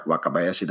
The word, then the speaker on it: Wakabayashi: You can search my name, Osamu, O-S-A-M-U. Now, Wakabayashi, that's my last name. I have to spell that for you. Wakabayashi.